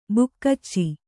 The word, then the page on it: ಬಗೀಚೆ: bagīce